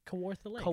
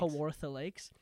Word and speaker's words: Kawartha Lakes: Kawartha Lakes. – Kawartha Lakes.